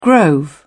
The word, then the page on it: grove: British English